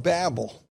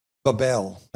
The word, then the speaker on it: Babel: And I won't take the time to read that, but Nimrod will head up the kingdom of Babel, or as Doc calls it, we won't tell him, Babel. That's how Doc pronounced it. And anyway, Nimrod will head up the kingdom of Babel, or Babel, whichever you want to pronounce it,